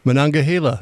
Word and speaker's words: Monongahela: The Monongahela. – Hey, can we get a pronouncer on this? – Monongahela.